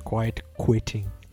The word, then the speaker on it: quiet quitting: Começaram a falar sobre esse tema e, é claro, nós que somos os especialistas, vamos falar também, que é quiet quitting. É isso que eu falei certo, prof? – Quiet quitting, por favor.